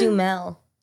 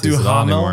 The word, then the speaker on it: Duhamel: It's Dumel. – It's Dumel? – Yeah. – Yeah, not Duhamel anymore. it's spelled Duhamel.